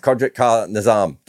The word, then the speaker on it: Kudrat Ka Nizam: it's Kudrat Ka Nizam. Did I get that right? – Yeah. Yes. Pronounce it with a K. It's easy.